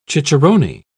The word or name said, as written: cicerone